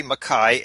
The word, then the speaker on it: Mackay: You make your way to Mackay. Is that how you say it? – That's right, Mackay, Australia, north of Brisbane.